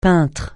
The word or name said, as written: peintre